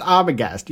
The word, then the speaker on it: Arbogast: I'm like, dude, he literally just said it's pronounced Arbogast. You don't need to, like, sound it out.